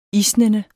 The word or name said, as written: isnende